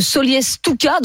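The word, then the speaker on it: Solliès-Toucas: On est avec Cyril qui nous appelle de Solliès-Touca dans le Var. Bonjour Cyril. – Bonjour. – Je ne sais pas si je prononce bien. – Si, c'est ça.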